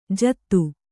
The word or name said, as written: ಜಾದೂ